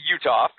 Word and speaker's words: Uthoff: Iowa took the lead thanks to, what is it, Jared Uthoff, Utiff, how do you say his name? – I don't know. – Well, it's Jared Uthoff is how you pronounce it.